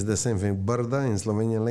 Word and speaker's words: Brda: How do you pronounce that? – Brda. Yes. – But that's how you say in Slovenian? – It's Brda? – It's the hills.